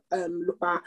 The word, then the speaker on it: Lupin: Okay, so basically it's spelled Lupin, but it's Lupin, that's how you pronounce it in French.